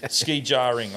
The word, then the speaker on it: skijoring: Pro tip, if you're curious about skijoring pronunciation, it's ski... Well, no, I'm kidding. Ski jarring. I've been saying it wrong the whole time, man.